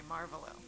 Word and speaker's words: Marvolo: No, they say Marvolo. – Marvolo? – It doesn't matter. Sometimes you put the wrong emphasis on the wrong syllable, but it's okay.